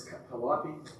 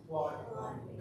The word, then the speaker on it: Calliope: You've got Gladstone, then you've got this place, I'd never say it right, but it's Calliope. Calliope. Calliope. I say it right now. I used to say Calliope. I said something silly.